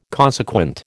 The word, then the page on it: consequent: American English